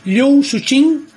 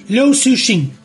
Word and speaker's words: Liu Cixin: Liu Cixin. – Liu Cixin. – Liu Cixin. Más o menos. – La C es una TS, digamos. – Liu Cixin. Casi. No sé. Estoy cerca. – Liu Cixin.